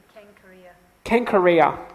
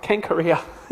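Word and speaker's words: Cenchreae: That would be a hard C, so it'd be Kenkorea. – Kenkorea.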